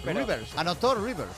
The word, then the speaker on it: Reuvers: ¿Cómo se pronuncia Reuvers? ¿Reuvers? ¿Reuvers? ¿Rivers? – Rivers. Hay que ir a la fuente. Es que como río en plural. – En ACB, Rivers, sí, Rivers.